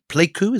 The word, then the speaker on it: Pleiku: base at Pleiku. Is that the right pronunciation? – That's right.